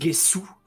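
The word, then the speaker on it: Gesù: C'est en italien, donc je sais pas la prononciation de via Gesu. – C'est Gesu.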